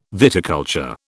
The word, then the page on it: viticulture: British English